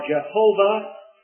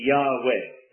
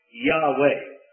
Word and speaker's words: Yahweh: Bible scholars, I'm not telling you anything that's new, Bible scholars are agreed that rather than Yahowah or Jehovah, it really should be Yahweh. They believe that that is the better pronunciation for this word, Yahweh. Now that's more familiar to you. Where you see the letters J-A-H,